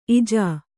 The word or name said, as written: ಈಜು